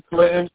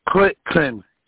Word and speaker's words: Clinton: It's like the president. Clinton.